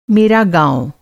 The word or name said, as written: मेरा